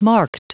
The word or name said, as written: marked